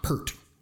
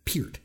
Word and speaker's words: Peart: Like it's Peart, like E, mm-hmm. like ear, with a P in front and a T at the end. Like not Pert, but Peart. But anyway, Neil Peart, greatest drummer who ever lived.